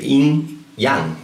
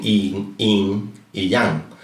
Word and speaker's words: Yin Yang: En chino se dice Yin Yang, no dicen Yin y Yang, porque lo consideran dos polos, pero a la vez lo consideran una unidad, dos fases de una sola cosa.